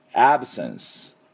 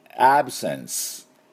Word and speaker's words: absence: あの口でえというような感じとかね、私は名古屋のおみゃーの音がこれにぴったり適すると思いますが、ね、最近ではですねトムとジェリーのトムの鳴き声にゃーおーって言うんですけど、あれがえのこの音ですね。で、 absence、こういう音になります。で、私の後について発音いってみたいと思います。いきますよ。 absence、 absence。